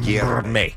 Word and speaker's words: Guillerme: You got to Y the L's and roll the R. – Trust me. I know. – Guillerme. – That's how you say it.